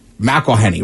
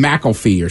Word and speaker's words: McElhenney: Rob McElhenney? – McElhenney, right. I always want to call him McElfee or something, but it's, yeah.